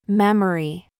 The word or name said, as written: mum